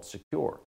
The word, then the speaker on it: Sekur: You need a new cybersecurity and privacy application tool called Secure. It's spelled S-E-K-U-R.